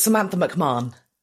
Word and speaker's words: Samantha McMahon: It's Samantha McMahon.